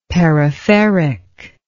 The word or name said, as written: peripheric